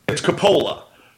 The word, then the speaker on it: Coppola: It's Coppola. – Okay. – The way you pronounce it is correct.